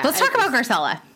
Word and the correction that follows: Garcelle: So Garcella. – No A. – Oh, Garcelle. Sorry.